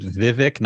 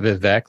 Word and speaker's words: Vivek: She also said Vivek, not Vivek. Like, she got his name wrong.